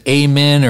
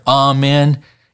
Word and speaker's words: amen: You can pronounce it amen or amen. It's your choice.